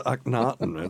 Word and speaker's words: Akhenaten: It was Philip Glass's Akhenaten. It's an opera. It's awesome. She's like, what, who's playing that awful music? – And that's exactly how it's said.